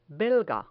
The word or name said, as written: belga